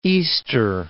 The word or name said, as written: Easter Island